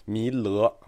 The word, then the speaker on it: 弥勒: 就给大家提到了将来未来佛弥勒菩萨要出世了。这个弥勒菩萨的出世，它还有两种形式：一种上升兜率天宫，这后来就形成了弥勒上升信仰；一种下生龙华树下，这就是弥勒下生信仰。注意啊，弥勒，我这发音没有问题吧？不是弥陀。